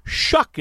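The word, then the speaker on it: Shough: or Tyler Shuck is how it's pronounced. You should probably know that by now, but it's my job just to make sure we get all the particulars aligned.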